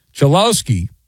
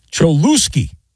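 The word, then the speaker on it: Cholowsky: Instead of correctly pronouncing the player's name, Chelowski, which is his name, Manfred pronounced his name Cholusky.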